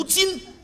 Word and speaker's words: 今: 今儿算我倒霉。 – 什么叫今儿啊？正字读今，当今世界有说当今世界的吗？ – 你叫什么真儿啊？